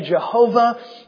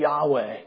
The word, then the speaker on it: Yahweh: It is God's name that sometimes people say Jehovah, but a better pronunciation of it is actually Yahweh.